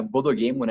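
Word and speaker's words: bodogemo (ボードゲーム): Bodogame, a gente pegou a palavra bodogemo, né, que é a pronúncia em japonês para board game.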